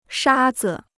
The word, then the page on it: 沙子: shā zi